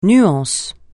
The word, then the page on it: nuance: francais